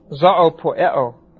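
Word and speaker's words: zoopoieo: It is the word zoopoeo, or zoopoeo. Z-O, long O-O, P-O-I-E-O.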